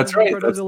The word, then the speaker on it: Cardozo: You went to Cardozo Law School? – You're pronouncing it the right way.